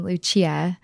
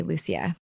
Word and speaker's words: Lucia: You can basically pronounce it however you want. It comes from Italian, Lucia, but here in the US, we generally say Lucia.